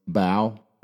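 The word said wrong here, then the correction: Lady Bow: Lady Bo, yeah.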